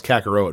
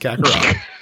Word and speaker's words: Kakarot: Whenever I see a word at an ROT, my dumb hockey brain always wants to pronounce it with a row sound. So I thought it was Kakarot at first, but it is not. It is Kakarot.